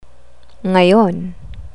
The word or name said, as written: ngayon